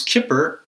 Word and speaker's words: Kippur: And Kephar, which we pronounce Kippur, means to cover.